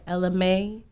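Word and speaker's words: Ella Mai: Also, did you guys hear Ella May? Because that's how you say her name.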